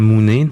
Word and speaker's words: mounet: Est-ce que vous pourriez me dire, ce que c'est que la mounet? Est-ce qu'on prononce comme ça? Oui, je crois. – Oui, c'est la mounet.